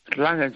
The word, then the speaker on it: Erlangen: Bueno, Gede, eh, una temporada más en la Bundesliga, ahora en el Erlangen, creo que se dice así, ¿no? – Erlangen, sí, Erlangen.